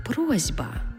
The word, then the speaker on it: просьба: Пропоную розглянути також слово «просьба». Ми точно знаємо, що це слово пишеться з літерою «с» – «просьба». Але як ми його вимовляємо? «Просьба». Чуємо звук «з».